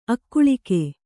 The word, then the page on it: ಅಗಲಿಕೆ: Kannada